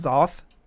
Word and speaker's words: Xoth: So that was the track The Ghost Hand of God from, I guess it's pronounced Xoth. It's X-O-T-H.